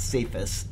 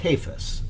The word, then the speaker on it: Cephas: There are some that I'm pretty certain of, like it's not Cephas, it's Cephas, okay, when you speak about the Apostle Peter.